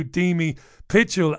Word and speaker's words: Demi Pietchell: Let's say hi to Deamy Pitchell.